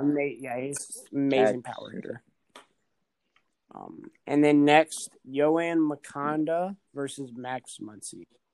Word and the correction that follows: Moncada: How do you say his name? What? Moncada.